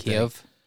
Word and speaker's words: Kyiv: From the capital of Kyiv to Lviv? I don't know how to... I'm butchering these names, but... – Kyiv? – Yeah.